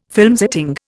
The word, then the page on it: filmsetting: Indian